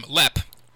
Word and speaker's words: Lepp: You know, my last name, Lepp, rhymes with Johnny Depp, and sometimes people don't know how to pronounce it. So I always say, you've heard of Johnny Depp. Well, Lepp rhymes with Depp.